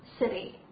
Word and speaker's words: city: 我们来读一下 city，啊，大家不要读成 city 啊，city。